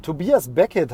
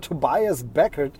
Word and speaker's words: Tobias Beckett: Ob das der äh, Tobias Beckett heißt er ja, Tobias Beckett, ähm, sehr, sehr seltsam, da so ein Name, der irgendwie äh, auf dem ersten Moment ein bisschen deutsch klingt, aber wird eben englisch ausgesprochen.